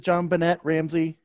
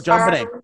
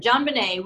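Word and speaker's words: JonBenét: Am I the only one who pronounces it John Bennett Ramsey? Is that just me? – Yes. Sorry. – Probably. – Okay. – Yeah, that's right. You. – Just checking. So the T's there, so I pronounce it. – Yeah, definitely. – You know, Knife. Knight. Pterodactyl. – Pterodactyl. – So, JonBenet.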